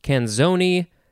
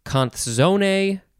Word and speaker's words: canzone: So it's spelled similarly to calzone, but there's an N, C-A-N, and then zone. So it can be pronounced canzoni, canzone, canzone, canzone, okay.